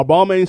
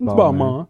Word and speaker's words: Balmain: Because are Balmain still in? Is that in right now? – For sure. – First of all, I'm not even saying it right. – It's Balmain.